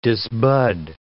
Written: disbud

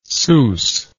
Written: seuss

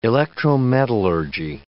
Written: electrometallurgy

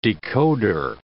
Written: decoder

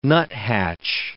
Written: nuthatch

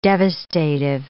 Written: devastative

